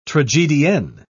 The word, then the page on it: tragedienne: British English